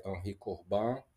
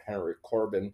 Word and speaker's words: Henri Corbin: by Henri Corbin or Henry Corbin, mm-hmm. in the American pronunciation, a very good author to read about him.